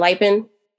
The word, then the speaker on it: Lipin: They often call him like Snipin' Lipin, because that's how you pronounce his name. Is not like it's, I always thought it was Lupin, but it's Lipin.